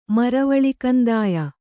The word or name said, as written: ಮರವಳಿಕಂದಾಯ